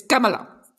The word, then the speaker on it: Kamala: Es Kamala.